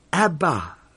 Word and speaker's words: Abba: Abba, Father. The Aramaic word for daddy, literally two syllable, just like ours, dada, Abba, Father.